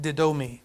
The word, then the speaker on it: didomi: I didn't stutter, that's actually how it's pronounced, didomi.